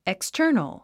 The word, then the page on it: external: ekstə́ːrnəl; エクスタァノォ